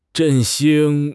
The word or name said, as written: 振兴